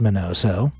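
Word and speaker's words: Miñoso: From stinky feet to white socks now, Minnie Minoso, pronounced Minoso,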